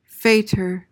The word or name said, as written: faitour